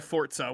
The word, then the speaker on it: Forza: So from now on, I'm referring to it again as Forza and instead of Forza, which is the correct pronunciation, mm-hmm.